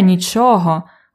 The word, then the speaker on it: нічого: Here нічого with a stress on the second syllable is a genitive form of ніщо. It's a pronoun here.